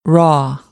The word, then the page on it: raw: American English; ɑː